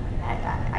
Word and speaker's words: Haggai: Do you pronounce this Haggai or Haggai? Haggai. I guess Haggai is New York.